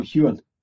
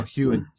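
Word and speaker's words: Heughan: It was on all the big talk shows, you know, both the main stars, you know, Katrina Balfe and Sam Hoogan, is it? – You wouldn't pronounce it like that. – How would you pronounce it? – It would just be Sam Hewen. – Oh, Hewen, right.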